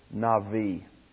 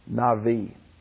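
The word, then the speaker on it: Navi (נביא): It's the Hebrew word Navi. N-I-A-B-I. B's are pronounced like V's. Navi.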